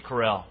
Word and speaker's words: Carell: It's Steve Carell, by the way.